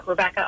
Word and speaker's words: Rebekah: It's Rebecca, R-E-B-E-K-A-H. Last name is Nagler, N-A-G-L-E-R.